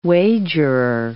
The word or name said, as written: wagerer